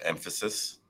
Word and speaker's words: Mphasis: It's Nitin, and the company is pronounced Emphasis. It's the emphasis without the E.